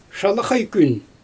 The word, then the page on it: ʃalaɣaj kyn: Xyzyl